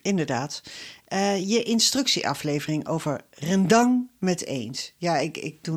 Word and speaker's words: rendang: En ik heb al gehoord dat het klemtoon meestal op de tweede lettergrepen ligt bij de uitspraak rendang.